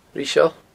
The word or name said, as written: Riseail